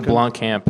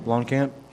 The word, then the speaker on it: Blomkamp: How do you say it? – Neil Blomkamp. – Blomkamp.